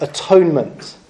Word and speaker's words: atonement: But when we get to Leviticus, we find out that a burnt offering is all about atonement. Now, atonement is a really important Bible word. In fact, in English, it used to be pronounced differently. We used to say it differently. We used to say at-one-ment.